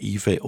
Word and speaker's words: Ife: Aus dem Album Big Fun hören wir Ife, I-F-E geschrieben. Ausgesprochen entweder Ife oder Ife.